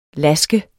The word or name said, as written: lask